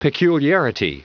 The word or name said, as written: peculiarity